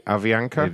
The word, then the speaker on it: Avianca: Avianca? A-V-I-A-N-C-A. Yes. Avianca? – Avianca.